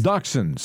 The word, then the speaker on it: dachshund: Dachshunds. – Dachshund, yeah, dachshunds. – That's how we pronounce it here in America.